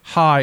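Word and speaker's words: hi (חי): Hi is a Hebrew word meaning with a symbol that means life. And it's pronounced as if you were saying hi. So it's spelled C-H-A-A-I.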